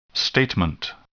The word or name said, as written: statement